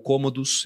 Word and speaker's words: Cômodo: Eu tenho moedas do Calígula, conta a história do Cômodo, ou Cômodos, se a gente for pegar a pronúncia né, mais para o latim, que é o filho do Marco Aurélio, imperador filósofo.